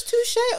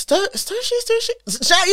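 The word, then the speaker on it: Stooshe: Stoosh, was this Touche or Stoosh? – Stoosh.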